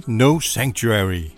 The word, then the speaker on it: No Sanctuary: No Sanctuary.